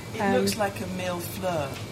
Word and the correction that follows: millefeuille: It does. – Am I saying it right? – Millefeuille. – Obviously, my French is really bad there. – It's slightly different.